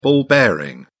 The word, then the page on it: ball bearing: British English